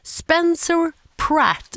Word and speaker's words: Spencer Pratt: Spence the Trap? – Nej, Spencer Pratt.